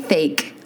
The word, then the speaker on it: Thake: Oh, it's first of all, he said, it's Ian Thake. Like okay. Cake. – Yeah. – And let me tell you this. It is spelled T-H-A-K-E. Fake. Cake. Exactly how it is spelled is how it should be said.